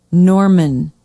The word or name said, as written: norman